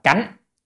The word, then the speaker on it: cánh: Chữ này đọc là cánh, cánh vô thắng tiến.